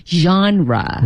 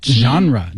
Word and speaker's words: genre: B-U-F-F, buff. – To be a buff about something.、Yeah. – 哎，什么控啊，什么迷啊之类的。Mm-hmm. 那咱们就说说电影吧，因为电影有很多的类型啊，不同的 genre。 – Right. – 哎，这个单词大家一定要知道哈，怎么拼写来的？Genre, G-E-N-R-E, genre. – Genre. 还有这个单词的发音有点费劲哈，我们再读一遍 genre. – Yeah, genre.